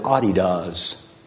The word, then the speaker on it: Adidas: We've Americanized it. It's really pronounced Nike. But we've Americanized Adidas too. Adidas comes out of Germany. They pronounce it Adidas.